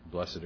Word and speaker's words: blessed: But when I was looking at this a little more, and I looked at this word B-L-E-S-S-E-D, I realized that I personally would rather pronounce it as though it's B-L-E-S-T, blessed, because that gives the idea that somebody is doing it to you rather than saying this is a condition you have.